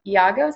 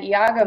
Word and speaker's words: Iago: So basically, Iago, is that how you say it? – Yeah.